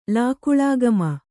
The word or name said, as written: ಲಾಕುಳಾಗಮ